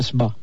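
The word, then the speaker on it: sbꜣ: Por ejemplo, se pronuncia Sba. De la misma forma que los egipcios escribían Sba, o sea, que significa estrella, también escribían Puerta.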